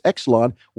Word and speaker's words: YXLON: Obviously, the first question, Exelon, which is Y-X-L-O-N. It's how it's spelled, but it's pronounced Exelon.